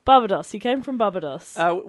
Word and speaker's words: Barbados: I thought that as well, but now I'm looking and I'm thinking, maybe is it Barbados? Is that right? Am I saying that right? – Barbados.